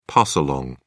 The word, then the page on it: pass along: British English